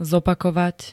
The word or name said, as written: zopakovať